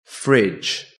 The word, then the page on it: fridge: British English